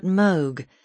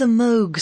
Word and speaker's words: Moog: Although the Moog synthesizer, the word rhymes with vogue, not fugue,